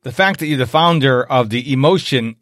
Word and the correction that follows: Emovation: Did I say that right? Emovation? Emovation. – Emovation. Yeah, Emovation, you nailed it.